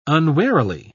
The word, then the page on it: unwarily: British English